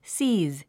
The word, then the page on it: seize: síːz; スィーズ